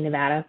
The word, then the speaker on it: Nevada: And Aubrey, I appreciate you pronouncing Nevada correctly.